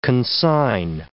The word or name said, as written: consign